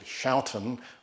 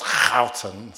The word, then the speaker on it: Schouten: And this issue was actually looked at by a Dutchman called Schouten, although I think it's pronounced Houten, something like that.